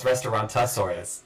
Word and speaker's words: Restaurantosaurus: Well, Bella keeps going Restaurantosaurus when we were talking about it. And I was like, no, it's Restaurantosaurus. – No, Restaurantosaurus. Restaurantosaurus. You're adding an extra syllable.